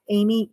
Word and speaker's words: Amy: Yeah, you can follow me on Facebook at Amy Enos, A-M-Y, Enos, E-N-O-S.